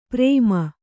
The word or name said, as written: ಪ್ರೇಮ